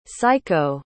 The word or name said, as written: saiko